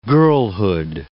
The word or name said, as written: girlhood